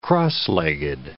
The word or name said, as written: cross-legged